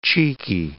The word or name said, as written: cheeky